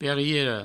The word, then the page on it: periera: Provençal; periero